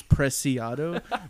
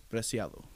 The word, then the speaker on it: Preciado: Well, that's the white name or white way to pronounce my name is Preciado, but my last name is Spanish, so it's Preciado.